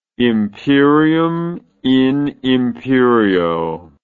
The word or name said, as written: imperium in imperio